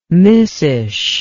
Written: missish

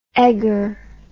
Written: eggar

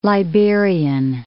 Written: Liberian

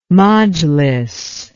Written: majlis

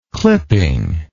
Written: clippingly

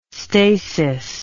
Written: stasis

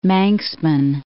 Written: Manxman

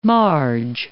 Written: marge

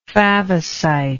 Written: favosite

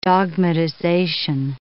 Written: dogmatization

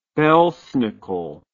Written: belsnickel

